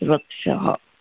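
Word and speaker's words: Vrakshaha (वृक्षः): The two dots, it's called Visarga, Vrakshaha. That's how it's pronounced, Vrakshaha.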